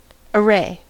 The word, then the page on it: array: American English